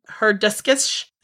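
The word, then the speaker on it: Hurðaskellir: Yule lad number 7, which is pronounced Herduskish, his name translates to door slammer.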